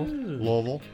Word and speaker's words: Louisville: Louisville. Louisville. – Louisville. Louisville. – That's how they say it. Box rocks in your mouth. Gotta have bo- box of rocks in your mouth.